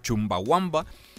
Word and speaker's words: Chumbawamba: Estamos de regreso en Fútbol en Red y usted acaba de escuchar a la agrupación de Chumbawamba con el tema Top Thumping. ¿Lo dije bien, señor Salgado? – Yes, very well.